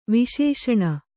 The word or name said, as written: ವಿಶೇಷಣ